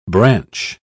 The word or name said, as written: branch